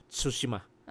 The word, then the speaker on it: Tsushima: Pero I think the Japanese would uh, pronounce this at, as, ano, as Tsushima.